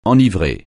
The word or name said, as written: enivrer